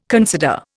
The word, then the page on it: consider: Indian